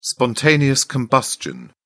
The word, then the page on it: spontaneous combustion: British English